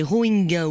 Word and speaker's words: Rohingya: Les Rohingyas ou Rohingya, la prononciation dépend des régions, cette minorité musulmane réprimée depuis le 25 août dernier, depuis une attaque d'un groupe rebelle Rohingya contre des policiers.